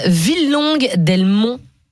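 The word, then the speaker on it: Villelongue-dels-Monts: Villelongue d'Elmont. C'est comme ça que ça se prononce ? – Oui, c'est comme ça.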